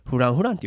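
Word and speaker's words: Francfranc: とかあるとこねそうそうそうであそこで僕が「うわここめっちゃおしゃれななフランフランクフランクっていうの?」って言ったら、そいつが「もう何言うてんねんこれなフランフランって読むねん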